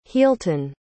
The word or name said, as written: healhtun